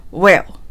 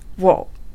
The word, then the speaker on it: Will: Will は強形で発音すると私たちのよく知っている Will という発音になります。そして弱形で発音すると今聞いたような Whoa のような音になるんですね。つまり発音が 2 種類あるということになります。